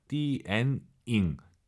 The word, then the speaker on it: 电影: 디엔잉 니은 발음 들리면 안 돼요. 디엔잉 디엔잉 다시 들어볼게요.